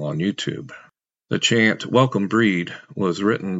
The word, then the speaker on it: Brigid: That was Welcome Breed, Breed being the Irish pronunciation of Brigid.